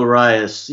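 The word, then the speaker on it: Urías: To get people caught up to speed if you missed it, and am I pronouncing that right, Ben, Urias? – Yeah, Julio Urias, he was a pitcher who signed out of the Mexican League.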